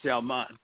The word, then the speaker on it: Sallman: Warner, like Warner Brothers, and then Salmon is like Sallman. Warner Salmon.